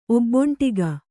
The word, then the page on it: ಒಬ್ಬೊಂಟಿಗ: kannada